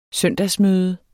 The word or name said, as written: søndagsmøde